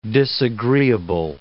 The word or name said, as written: disagreeable